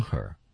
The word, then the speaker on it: her: Upon her, 那个 her, h, 也会在发音当中被怎么样呢？ – 省略掉。 – 被省略掉 upon her。